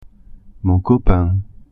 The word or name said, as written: copain